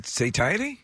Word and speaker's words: satiety: And Penn State University researchers say that it's due to a sensory-specific satiety. I'm not sure how you pronounce this. – As in satiate? – I guess so. S-A-T-I-E-T-Y. – So satiety? – I looked up the pronunciations. Yeah. It's satiety.